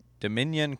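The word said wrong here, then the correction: Dameyune: Craig from Auburn. – Damian Craig.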